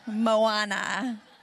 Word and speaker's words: Moana: Thank you, Wes, for telling me how to pronounce Moana.